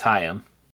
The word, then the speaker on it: Haim: It's Haim.